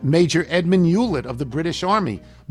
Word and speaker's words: Hewlett: I didn't immediately make the connection because you never pronounced the H. Because it's Hewlett. Well, how am I supposed to pronounce it?